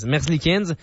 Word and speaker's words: Merzlikins: Ils reçoivent aussi le gardien Elvis Merzlikins, c'est-tu comme ça qu'on prononce, les gars? – Merzlikins.